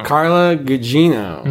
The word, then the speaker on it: Gugino: Carla Gugino.